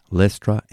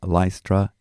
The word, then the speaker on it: Lystra: It's pronounced Lystra in British English and Lystra in American English, and forgive me, I've spent time in both places.